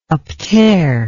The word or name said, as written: uptear